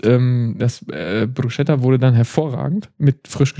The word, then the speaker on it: Bruschetta: Bruschetta.